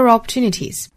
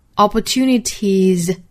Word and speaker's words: opportunities: Consider opportunities, consider opportunities. Opportunity 这个词变复数的话呢，尾部是变 y 为 i 加 es, 所以它的尾部发音呢应该是要读浊音的。 Opportunities, tis, opportunities now. Better to consider opportunities now. 我们在发音的时候要知道这个尾部是浊音，不要不注意哈，把它发成了那个清音就不对了。